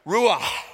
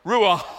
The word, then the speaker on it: ruach: Ruach, that's a guttural there. You're supposed to pronounce it like someone's choking you to get it accurate in Hebrew. Ruach, you know.